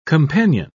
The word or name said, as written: companion